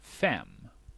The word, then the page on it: fem: /fɛm/